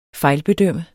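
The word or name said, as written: FEJLBEDØMMES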